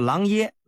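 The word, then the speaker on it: 琅邪: 狼邪郡的干鱼有一个叫徐福的方士，就是古代啊求仙啊炼丹的人，跑到狼爷这儿给这秦始皇上书，说他有办法啊寻觅到这个仙药。 – 听众朋友们，这个狼邪也叫狼爷，这个邪啊是个多音字，喜耶邪或者啊就念耶。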